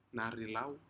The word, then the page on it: narilau: Aneityum